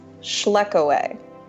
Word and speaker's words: Schlekewey: My last name is Schleckaway.